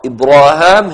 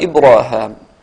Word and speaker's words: إبراهام: إذن الألفاظ التي تقرأ إبراهام هي ألفاظ معينة محددة حددها الشاطبي رضي الله عنه من السور التي ذكر فيها هذا اللفظ. قرأها هشام هكذا إبراهام، وهي مواضع متفرقة في القرآن ذكرناها مضمنة في الأبيات.